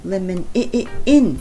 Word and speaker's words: lemon: なので、まあ、レモンをレーメンと覚える必要はありませんけど、まあ、 ON は IN と発音することも多い。特に終わりの時に来て N があった場合ですよね。 ON って来るものは On って発音するよりも Lemon in って発音することが多いんではないかなと思います。